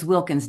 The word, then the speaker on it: Wilkins: M-Y-S-H-E-L. And then my last name is Wilkins. W-I-L-K-I-N-S.